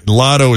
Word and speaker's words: Lado: Lotto is spelled L-A-D-O, by the way, even though it sounds like Lotto, L-O-T-T-O.